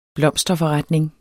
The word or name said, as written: blomsterforretning